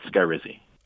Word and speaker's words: Skyrizi: Yeah, it's Skyrizi.